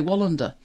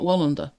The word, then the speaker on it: Wallander: But over here, people say Wallander. Mm. It's not Wallander. It's Wallander.